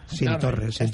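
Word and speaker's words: Cinctorres: Cinc Torres. – Pero es con C. – Bueno, conoces. – Es con C, se escribe con C, pero pronunciamos S.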